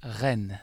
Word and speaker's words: Rennes: Rennes.